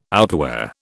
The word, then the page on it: outwear: British English